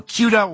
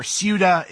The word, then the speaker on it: Ceuta: So Ceuta, or Ceuta, is, I've heard it pronounced both ways, is a part of Spain that is actually on the northwesternmost part of Africa.